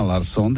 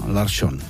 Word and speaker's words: Larsson: V případě výslovnosti sikavek převážila severská verze změkčení po písmenu R, tedy jména jako Persson a Larsson,